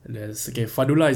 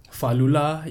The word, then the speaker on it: Fadlullah: So there's Fadlullah is a normal one. – Yeah. – Fadlullah is another one.